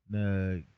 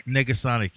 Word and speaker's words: Negasonic: Negasonic. – Negasonic, yeah.